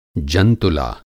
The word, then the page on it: जंतुला: Hindi